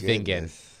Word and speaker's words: Vingen: He was calling me Vignon. – Oh, Vignon. – Rhymes with Mignon. Okay. I was like, unfortunately, it's Vingen.